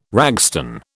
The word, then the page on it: ragstone: British English